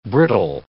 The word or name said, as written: brittle